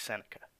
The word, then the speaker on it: Seneca: Seneca cigarettes. Hold on. Seneca. S-E-N-E-C-A.